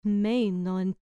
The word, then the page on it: meino: grec